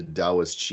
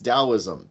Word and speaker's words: Taoism: further divert this into Taoist chi theory, which, by the way, personal gripe, I keep hearing people say Taoist. Please stop. It's Taoism. It's how you say it correctly.